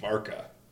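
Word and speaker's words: Warka: I think in Polish, W's are already is V. – They're pronounced as a V, so I feel like we're already off to the wrong foot here because we should be saying Worka.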